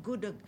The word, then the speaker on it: Gudeg: Gudeg? – Gudeg.